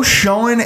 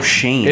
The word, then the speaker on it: Schoen: Now the front office for the Buffalo Bills is finally coming into focus as they introduce Brandon Beane, Joe Schoen, and Brian Gaine. I believe it's Joe Shane. – It is Joe pronounced Shane. Shane. Shane. Shane. It's like Seamus.